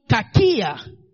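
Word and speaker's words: kakia (κακία): The word understanding, as used here, is the Greek word kakia. The word understanding, as used here, is the Greek word kakia. Kakia is spelled K-A-K-I-A.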